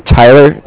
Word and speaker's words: Tyler: My name is Tyler. It's T-Y-L-E-R. And I'm with Cardiff. It's spelled C-A-R-D-I-F-F, as in Frank.